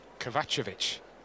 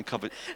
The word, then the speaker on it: Kovacevic: How do you say his name? – Kovacevic. – No, it's not, is it? – Kovacevic. – Oh, is it? Oh, yeah, I've been saying Kovacevic.